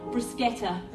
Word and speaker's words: bruschetta: We'll cross eyes over a crowded cafe as he explains to the waitress that no, it's actually pronounced bruschetta.